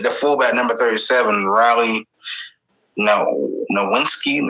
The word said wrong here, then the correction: Nowakowski: I cannot pronounce that. – Nowakowski.